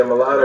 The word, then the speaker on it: Mulatto: Mulatto? – Oh, yeah, Mulatto. – That's how you say it. Yeah, Mulatto, she was definitely the best one out of all of them.